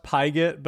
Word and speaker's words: Pigott: My last name's pronounced Pigott, but to be honest, I mean, I'll respond to most things, which is awesome.